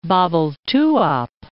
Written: Babelthuap

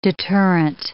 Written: deterrent

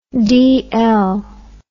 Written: dl

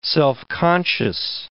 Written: self-conscious

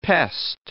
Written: pest

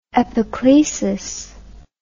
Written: epiclesis

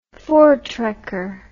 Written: voortrekker

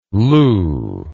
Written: lew

